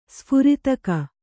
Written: ಸ್ಫುರಿತಕ

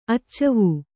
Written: ಅಚ್ಚವೂ

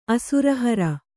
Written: ಅಸುರಹರ

